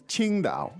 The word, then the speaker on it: Qingdao: impress with your tones. Because it's Qingdao.